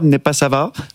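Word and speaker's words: Népszava: C'est un article du média hongrois, Népasava. Je le prononce bien prononcé.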